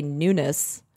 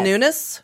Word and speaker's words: Nunes: I think you're supposed to say Nunes. – Oh. – It's N-U-N-E-S. – Is it Devin Nunes? – But everyone loves to be like Nunes.